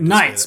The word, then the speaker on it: Knights: And the one that took the most votes was Restless Knights Inn, like K-N-I-G-H, whatever. – Right, Knights, but the K is silent.